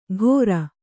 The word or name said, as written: ಘೊರ